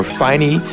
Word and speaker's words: Phynee: His name is Finney, or Finey. It's spelled P-H-Y-N-E-E.